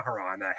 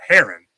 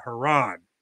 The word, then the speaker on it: Haran: or not Haran, Haran. Keep getting that one confused, the other word. And then in Genesis 11:32, it says that Terah lived 205 years and he died in Haran. Correct pronunciation of that word.